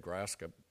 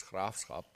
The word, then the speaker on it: Graafschap: That's how Americans say it, Graskop. Dutchmen say it as Graafschap. That's the correct pronunciation.